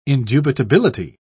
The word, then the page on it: indubitability: British English